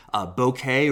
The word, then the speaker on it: bokeh: Bokeh or bokeh, tomato, tomato, as to how you want to pronounce that word. But it's spelled B-O-K-E-H.